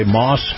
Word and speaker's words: Maus: Our conversation with Chris Markowski is brought to you by Moss Family Automotive. Moss is spelled M-A-U-S,